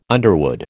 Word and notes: underwood: American English

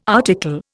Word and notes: article: Indian

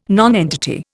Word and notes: nonentity: Indian

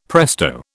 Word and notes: presto: British English

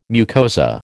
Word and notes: mucosa: American English

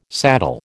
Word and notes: saddle: American English

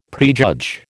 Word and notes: prejudge: American English

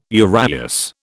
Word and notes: uraeus: British English

